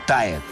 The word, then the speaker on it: Тает: Там «А» и «Е» слитно, поэтому «Тает». – А вот как это произносится правильно? «Тает»? – Да, вот как я и стараюсь. Ну да ладно, к слову, именно «Тает». Хотя этот дифтонг я по латыни прочитал бы как «Тет».